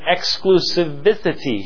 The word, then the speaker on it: exclusivity: There is an exclusivity here. Exclusivity. There we go. Don't need that extra syllable in there.